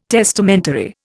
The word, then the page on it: testamentary: Indian